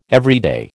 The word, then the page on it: everyday: American English